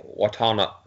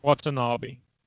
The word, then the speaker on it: Watanabe: And I wanted Ken Watanabe. How do you say his name? Ken Watanabe. – Watanabe, yeah.